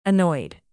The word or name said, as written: annoyed